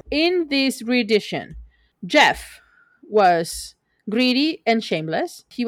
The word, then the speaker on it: Geoff: That's Jeff. – Jeff Blythe. – No. – G-E-O-F is pronounced Jeff. – It has two F's. – It's still pronounced Jeff. No. It's French. – Jeff Blythe. B-L-I-T-H-E.